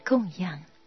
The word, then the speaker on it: 养: 一般人不但对待众生不会先研究，连供养佛也很少先研究了解，佛是应当如何供养？我们平常都听说供养佛、供养法，供养的养读作第四声。自古这个养字，对上要读作供养、孝养第四声，对下才读作第三声养，比如教养、养育。